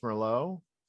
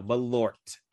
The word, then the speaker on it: Malort: Is not pronounced Merlot? – No, Malort. M-A-Lort.